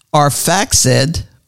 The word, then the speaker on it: Arphaxad: How you pronounce A R P H A X A D. that's how you pronounce that. Yes, and we know that I got a smart wife.